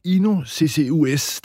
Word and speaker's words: INO-CCUS: Carina Søgaard, du er direktør for INO-CCUS. Det er lidt svært at udtale, men hvad er I sat i verden for at løse? – Ja, du udtaler det helt rigtigt, vil jeg sige.